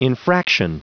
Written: infraction

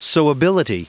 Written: sewability

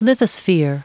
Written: lithosphere

